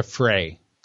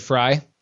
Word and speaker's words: Frey: Oh, and it's sorry, uh, just to clarify, it's Jessica Frey. You said Fry.